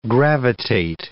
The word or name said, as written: gravitate